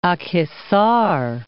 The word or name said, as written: Akhisar